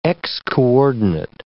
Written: x-coordinate

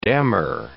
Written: damar

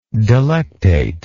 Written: delectate

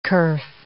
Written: kerf

